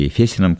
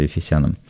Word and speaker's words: Ефесянам: Так, послание апостола Павла к Ефесянам, или Ефесянам, как многие произносят, но я уже так вот по старой традиции Ефесянам.